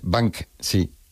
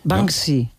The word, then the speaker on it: Banksy: en España la gente lo dice de otra manera, ¿no? Pero bueno, como Banshee. – Esas palabras... Es Banksy. – Banksy.